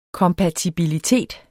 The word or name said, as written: kompatibilitet